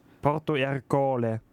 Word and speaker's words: Porto Ercole: 1608 flieht er quasi von Malta und 1610 stirbt er in Porto Ercole. – Also in irgendeiner Hafenstadt höchstwahrscheinlich. – Ja. Ja. Willst du nochmal die Aussprache kontrollieren? – Stimmt. Alles gut. – Porto Ercole.